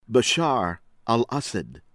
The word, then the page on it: Bashar Al-Assad: American English